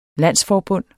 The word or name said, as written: landsforbund